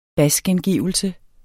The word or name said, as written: basgengivelse